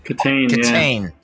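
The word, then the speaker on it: Kitaen: Am I mispronouncing it? Does anybody know? – Kitan.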